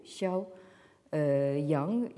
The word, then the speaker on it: Xiao Yang: Alors, le chercheur chinois qui nous intéresse plus particulièrement dans le contexte présent s'appelle Xiao Yang. Alors donc en transcription, ça s'écrit X-I-A-O, Xiao Yang, Y-A-N-G.